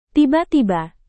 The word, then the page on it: tiba-tiba: Indonesian